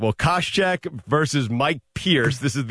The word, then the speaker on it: Koscheck: The welterweight bout, Josh Koscheck. Am I saying that right? – Yeah, you deliberately butchered that because I know you know who this guy is. – I really don't. – Koscheck. – Okay, yeah.